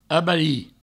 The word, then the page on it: abarir: abari